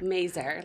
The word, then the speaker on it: Mazur: Mazur, like laser. – Ooh, okay. – So there's an S, but it's pronounced with a Z.